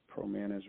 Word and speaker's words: Promanus: Promanus rhymes with bananas.